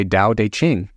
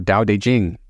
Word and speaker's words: Tao Te Ching: I would say Tao Te Ching, or Tao Te Ching. I've heard it pronounced many ways.